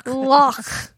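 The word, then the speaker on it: loch: And so Ian and Duke took their contraption and a camera to the lock and they photographed it. – That's not how you pronounce the lock. – It's pronounced lock.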